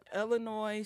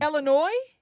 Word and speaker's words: Illinois: You still put the S on it. – Illinois? – No, you said Illinois State Fair.